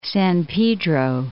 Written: San Pedro Channel

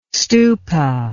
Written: stupa

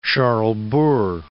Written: Charlesbourg

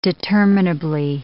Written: determinably

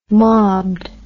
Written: mobbed

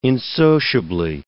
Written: insociably

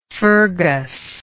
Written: fergus

